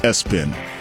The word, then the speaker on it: ESPN: The name ESPN, pronounced Espen.